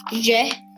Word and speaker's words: je: Je, spelled J E, a little bit like J, but it's pronounced je. Je.